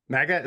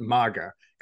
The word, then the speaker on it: MAGA: MAGA is that how people pronounce it? So I've always pronounced it MAGA because I did Krav Maga, so I was like, it's kind of like more like a Ma make, uh, mm.